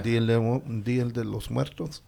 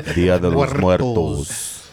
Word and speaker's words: Día de los Muertos: Día de los Muertos. – There you go. – Roll your R's, eh? – Roll your R's. You say good too, hey. – Día de los Muertos.